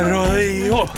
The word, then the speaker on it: Rojo: Song, Olivia Rojo. Här är morgonpasset i P3. Det är spanskt efternamn. – España. – Därför jag uttalar det sådär.